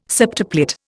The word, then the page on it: septuplet: Indian